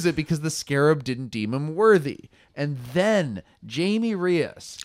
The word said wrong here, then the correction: Jaime: Jaime. Jaime? – Yeah, Jaime. Jaime.